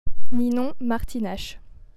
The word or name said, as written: Ninon Martinache